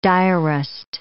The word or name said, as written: diarist